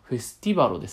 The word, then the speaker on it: FESTIVALO: えー、 F-E-S-T-I-V-A-L-O。フェスティバロって読むんですかねこれ。あ、フェスティバロですね。